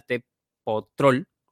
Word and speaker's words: Tecpetrol: También está por ahí, he visto que el 10% em, del, consorcio lo tiene la española Repsol, la argentina Tecpetrol, Petrol, perdón, así se, así se pronuncia.